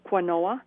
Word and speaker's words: quinoa: So then sliding right into my original question, why I called, was I know that, I know I'm going to pronounce this incorrectly, but quinoa? – Quinoa. – Nothing like it's spelled, but quinoa? – Yep.